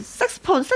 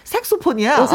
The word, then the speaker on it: 색소폰: 근데 우리가 이제, 색스폰, 색스폰 그러잖아요. 옛날에는 네. 다 그랬어요. 근데 요즘에는 또 표준 발음에 색소폰이야.